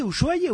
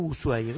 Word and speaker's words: Ushuaia: ¿Se dice Ushuaia o Ushuaia? ¿Cómo se dice? – Afuera le dicen Ushuaia. Acá creo que es Ushuaia. – ¿Y usted que es un gran pronunciador? – Ushuaia. – ¿Yanicero se dice Yanicero? – Afuera sí le dicen Ushuaia por la SH.